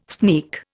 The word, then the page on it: sneak: Indian